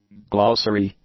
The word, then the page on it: glossary: english